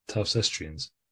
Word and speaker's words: Towcestrians: I think I played Towcesterians when they've come up to Nottingham on tour. – Towcesterians? – How do you say it? – Towcestrians.